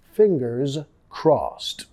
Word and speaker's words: fingers crossed: Fingers, sonido Z al final, fingers crossed. Crossed, ed pronunciado como una T.